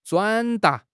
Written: 转达

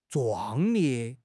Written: 壮烈

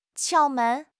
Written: 窍门

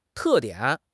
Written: te4 dian3

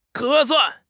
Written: 合算

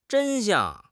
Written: 真相

